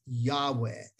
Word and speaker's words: Yahweh: So the best searches and researches of Hebrew scholars has shown to us it probably was 99% sure pronounced Yahweh.